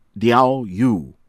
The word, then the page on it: Diaoyu: American English